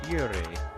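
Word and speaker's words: Aoire: That is from Houses of the Damned, Rags of Light, and Iore. Okay, that's A-O-I-R-E. I think I looked it up once, and the artist said, you pronounce it better than I do, so I don't know.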